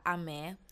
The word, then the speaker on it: Ameh: So today with me, I have Peter Ameh.